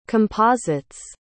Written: composites

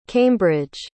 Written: cambridge

